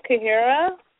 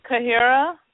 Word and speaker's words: Kahira: Kahira. – Spell it, because I know I'm going to mispronounce it. – K-A-H-I-R-A. – K-A-H. I-R-A, Kahira. – Yes.